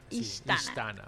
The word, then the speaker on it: Istana: En este sentido entonces vamos a entrar ahora sí en materia para que nos comentes un poquito de qué es Istana. ¿Está bien pronunciado, sí? – Istana.